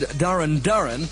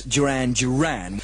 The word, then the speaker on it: Duran Duran: I said Darren Darren and Planet Earth at number 26, when of course it should be, as everybody knows, Duran Duran.